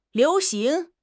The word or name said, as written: liu2 xing2